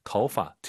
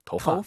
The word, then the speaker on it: 头发: 오유는 어우예요. 도우 하지 마시고 터우예요. 이성경성이니까 아하. 头发. 재밌는 건 이 파를 사성어로 발음을 정말 많이 해요. 头发.